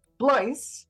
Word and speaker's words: Blois: And Steve Bloy. Am I saying that right, Steve? – Close enough, it's Blois. – Blois, who is Vice President, NARMAC, and sitting member of the Monterey County Reservoir Operations Committee, or ResOps.